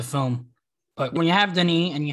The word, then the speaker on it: Denis: But when you have Denis Villeneuve directing something with Is that the proper pronunciation? – Yeah, Denis.